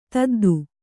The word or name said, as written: ದೊದ್ದೆ